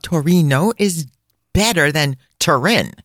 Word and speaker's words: Torino: Allora, we are very excited to tell you about the fabulous city of Torino, which is sadly referred to in English as Turin or Turin. However, I think it should be only pronounced in the beautiful name Torino. – Certo, Torino, without the roll of the R.